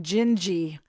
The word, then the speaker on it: Dindi: D-I-N-D-I is how it is spelled, but it is pronounced Gingy because it's Portuguese.